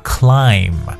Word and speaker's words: climb: 在很早之前的节目当中，我跟大家有去说过，英文单词凡是以 n b 结尾的话呢，b 都不发音。 OK，我们说过，比如像爬山的爬 climb，对不对？